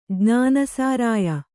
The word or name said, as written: ಜ್ಞಾನಸಾರಾಯ